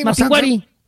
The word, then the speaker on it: Mapinguari: Ahí dice Mapinguari. – Dice Mapinguari. Ah, es que no tiene acento en la I. – No tiene acento en la I. – Mapinguari.